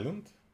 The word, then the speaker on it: Island: Skull, Skull Island. – Iceland. – Yani Island diye okunuyor. – Island'e o zaman niye o S var?